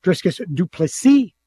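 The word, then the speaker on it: Dricus Du Plessis: Sean Strickland versus Driscus Duplessis. That's how you say it, by the way, even though everybody butchers it.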